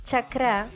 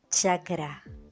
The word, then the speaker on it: chakra: pero lo que más se le parece a la pronunciación del mismo es chakra.